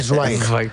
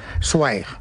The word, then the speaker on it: Zweig: Por el camino bien, ahora hay que decir Zweig. – Zweig. – Zweig. Stefan Zweig. – Es así como se pronuncia en alemán.